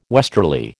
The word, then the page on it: westerly: American English